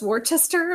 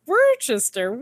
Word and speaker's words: Worcester: But Nate says Worcester and pronounces it the Massachusetts way.